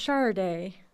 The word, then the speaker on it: Sade: If you ever wanted to know how to pronounce that, it's Sade.